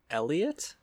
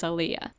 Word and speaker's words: Aaliyah: Elliot? – It looks like I would pronounce it as Aaliyah. – You thought, I thought it was Elliot. Aaliyah does make sense.